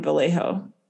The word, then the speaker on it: Vallejo: They decided to bring her to Huntington Beach, her hometown, away from the commotion of the authorities in Vallejo.